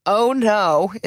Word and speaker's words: OYNO: And bonus if you know what these mean, but Oh No is one. It's spelled O-Y-N-O. – Looks like Oino. – Oino. But I would say, oh no, Oino. Or Mir, M-I-R.